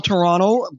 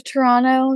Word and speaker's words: Toronto: We don't pronounce the second T? – Don't pronounce the second T. – A lot of Toronto, it's, yeah.